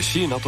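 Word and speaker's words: 4NA: 数字の 4 にアルファベットで NA と書いて椎名と読みます。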